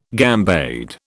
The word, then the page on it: gambade: British English